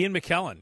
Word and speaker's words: McKellen: Ian McKellen, yeah.